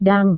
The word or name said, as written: dong